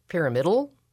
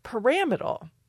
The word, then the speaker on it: pyramidal: Pyramidal? – That's exactly what I went for. – Yeah. – And I got stopped. And I got corrected that the pronunciation was pyramidal.